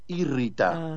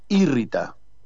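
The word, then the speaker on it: írrita: Se llama cosa juzgada irrita. – Ah, sí. – Con acento en la primera I, cosa juzgada irrita.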